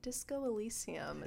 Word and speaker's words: Disco Elysium: Disco Elysium. I think I'm pronouncing it right. – Disco Elysium.